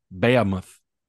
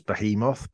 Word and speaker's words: behemoth: It's just incorrectly pronounced. It's behemoth, but I mean, if he wants to say it how he wants to say it, he's the heavyweight champion of the world.